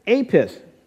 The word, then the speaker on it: Apis: They had Apis. That's the way it's pronounced.